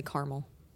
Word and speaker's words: caramel: They don't realize there's an A in the word. If you're out there and you say caramel, you're saying it wrong.